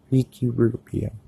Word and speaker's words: Ricky Rubio: Rookie Rubio. Ricky Rubio, sorry.